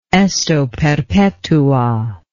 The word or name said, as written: esto perpetua